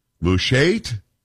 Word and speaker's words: Mushait: I don't know how to say this. M-U-S-H-A-I-T. Mushait? Can we say it? We're going to go with that. Paul, are we good with that? You're not done with that, are we? – Say it the way you need to say it. – Mushait? – Yes. – Yes, that's good. That's good.